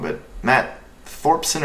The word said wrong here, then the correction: Thorson: or Thorson. Anyway, he's Canadian. but it's great. That game debuted, I believe, at Fantastic Fest last year. Matt Thorson. Nailed it.